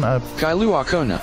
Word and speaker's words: Kailua Kona: Kailua Kona.